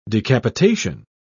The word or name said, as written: decapitation